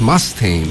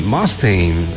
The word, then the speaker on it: Mustaine: Sí, es Mustaine. Mustaine. Algo así es la pronunciación exacta.